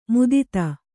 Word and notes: ಮುದಿತ: mudita

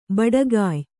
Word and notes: ಭಟ್ಟಗೆಯಿ: bhaṭṭa geyi

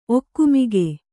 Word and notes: ಒಗುಮಿಗು: ogumigu

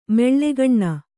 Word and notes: ಮೆಳ್ಳೆಗಣ್ಣ: Kannada

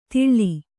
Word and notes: ತಿಳ್ಳಿ: tiḷḷi

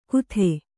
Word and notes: ಕುದೆ: kude

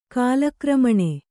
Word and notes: ಕಾಲಕ್ರಮಣೆ: kālakramaṇe

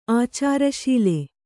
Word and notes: ಆಚಾರಶೀಲ: Kannada

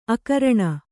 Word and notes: ಅಕರಣ: akaraṇa

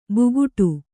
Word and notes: ಬುಗುಟು: buguṭu

